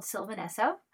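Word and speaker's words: Sylvan Esso: And I love this band, Sylvanesso. And I wanted to just highly recommend them to anyone who needs something new and different to listen to. And say the name again. – They're called Sylvanesso. – How do you spell that? – S-Y-L-V-A-N, the first word.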